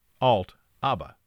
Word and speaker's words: Altaba: The Wall Street Journal says the word is a combination of alternate and Alibaba. So apparently it's pronounced Alt-ABBA.